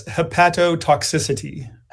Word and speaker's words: hepatotoxicity: I'll pronounce it as hepatotoxicity. – Oh, I like that.